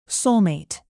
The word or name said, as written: soulmate